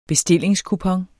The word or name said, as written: bestillingskupon